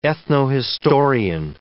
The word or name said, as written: ethnohistorian